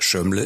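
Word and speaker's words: Zsömle: Schömle schreibt man Z-S-Ö-M-L-E.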